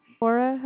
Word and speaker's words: Laura: My name is Laura. – Could you please say your name again? – Laura, how is Laura? L-A-U-R-A.